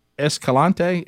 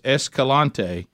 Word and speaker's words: Escalante: And what he's supposed to say is the grand staircase is at Escalante. Is that how you say it? – Oh, boy. – I think that's it. Yeah, I think that's it. – So grand staircase Escalante.